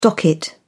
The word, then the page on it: docket: British English